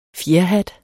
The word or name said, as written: fjerhat